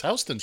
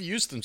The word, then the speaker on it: Houston: By the way, that's how it's pronounced here on the East Coast. – Oh, is that how it's pronounced? – It is Houston Street. We don't have Houston Street.